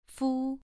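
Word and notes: fu: tone 2

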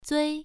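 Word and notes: zui: tone 1

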